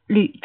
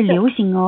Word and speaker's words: 旅: 啊，旅，注意发音，不是流行哦，流行是游行。